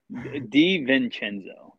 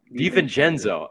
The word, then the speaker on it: DiVincenzo: Do you want to pronounce DiVincenzo for me? Am I saying that right? – DiVincenzo. – DiVincenzo. Oh my God. I should have known that.